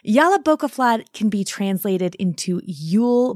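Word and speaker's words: Jólabókaflóð: So there are a lot of very opinionated people online adamant that the word is pronounced one way or another, but Jolabokaflod seems to be the consensus, so I'm going to use that here.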